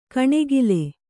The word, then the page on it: ಕಣೆಗಿಲೆ: kaṇegile